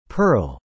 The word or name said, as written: pearl